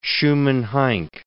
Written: Schumann-Heink

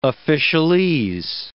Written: officialese

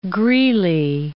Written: Greeley

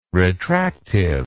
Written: retractive

